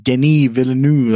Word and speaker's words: Denis Villeneuve: Dennis uh, I think it's pronounced Denis Villeneuve, like whatever, Dennis Villeneuve, the same guy who made Sicario.